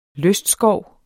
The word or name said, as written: lystskov